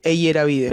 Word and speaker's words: Eyeravide: E-I-H. – Ese es. Porque mi apellido es larguísimo. Eyeravide. – Sí. ¿Cómo se pronuncia tu apellido? – Así, Eyeravide. – Pero todo el mundo dice Eyeravide. – Sí, pero lo dicen mal.